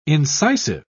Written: incisive

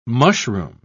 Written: mushroom

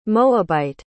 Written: moabite

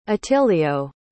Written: attilio

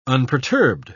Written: unperturbed

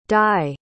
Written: dai

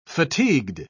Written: fatigued

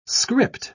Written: script

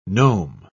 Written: gnome

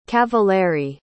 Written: cavalieri

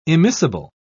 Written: immiscible

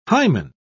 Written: hymen

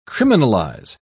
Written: criminalize

